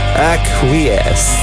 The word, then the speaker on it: Acquiesce: Acquiesce. Acquiesce. Zo zou je het volgens Sandra moeten uitspreken.